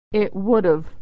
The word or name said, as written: it would've